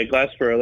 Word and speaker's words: Glassboro: Am I pronouncing that correctly? – Yeah, Glassboro.